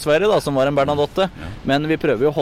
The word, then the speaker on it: Bernadotte: Selv Bernadotte han, er det mange som sier.